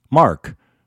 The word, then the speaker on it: Marc: I mean, I spell my name M-A-R-C, Mark, and I don't get upset when people pronounce it M-A-R-K, Mark.